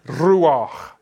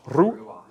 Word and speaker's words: Ruach: Ruach. Two syllables. Ruach. – Ruach.